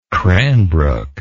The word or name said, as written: cranbrook